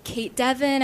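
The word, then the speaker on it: Cait Devin: Right, you can find me online at Kate Devon.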